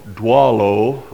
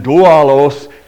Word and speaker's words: doulo: There is another very precious word which stands for deacon, and that is D-O-U-L-O, dwalo. I'm going to pronounce it that way because I heard a minister pronounce it that way.